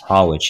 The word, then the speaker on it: Hollwich: I've heard you talk about the researcher, I don't know if I'm saying it right, Fritz Hollwich. – Hollwich, yeah.